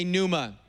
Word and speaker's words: pneuma: The New Testament word, the Greek word, is pneuma. Everybody say pneuma. The P is silent.